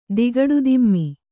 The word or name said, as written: ದಿಗಡುದಿಮ್ಮಿ